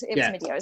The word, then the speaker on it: Mydeo: It rhymes with video. It was Mydeo. It's my video.